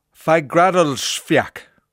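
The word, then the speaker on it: Fagradalsfjall: Fagradalsfjak. Fagradalsfjak. Fagradalsfjak. Fagradalsfjak. I think I have it. I think I have it. That's the pronunciation of an Icelandic volcano that has erupted over the weekend.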